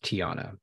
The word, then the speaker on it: Teauhna: And that is pronounced Teauhna. – Yes, please. Thank you. And if you say Teauhna, I'll still accept that.